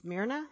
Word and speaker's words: Smyrna: Now, it's set in the city of Smyrna.